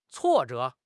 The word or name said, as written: 挫折